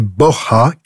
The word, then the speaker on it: Borja: Le vrai nom des Borgia est Borja, qui se prononce avec un H aspiré à l'espagnol.